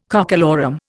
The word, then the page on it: cockalorum: Indian